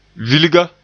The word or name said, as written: vilga